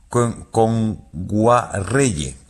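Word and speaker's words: Kngwarreye: Nguayere, lo he dicho mal. – Emily Kame. – Con Guarreye.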